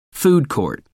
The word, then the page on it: food court: American English